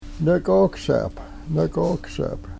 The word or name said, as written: nekoksep